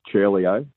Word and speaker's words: Ciurleo: It's pronounced Ciurleo. C-I in Italian is pronounced C-H. All right, ciao.